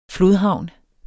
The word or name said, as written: flodhavn